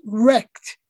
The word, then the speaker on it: RECD: you write, "Really existing capitalist democracy, RECD for short, pronounced wrecked, is radically incompatible with democracy.